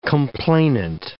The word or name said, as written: complainant